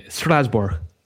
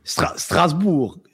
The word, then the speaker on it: Strasbourg: Strasbourg. Yeah. I've been there. – Right. Okay. But Strasbourg. – Yeah, I'm not perfect.